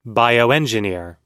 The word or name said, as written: bioengineer